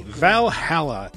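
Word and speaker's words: VA-11 HALL-A: I don't know how to pronounce it, so I'm just going to say Valhalla, even though it's a combination of letters and numbers. – It's written at VA-11 space HALL hyphen A, Valhalla, but it's the name of a bar.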